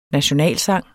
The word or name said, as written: NATIONALSANG